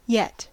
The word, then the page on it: yet: American English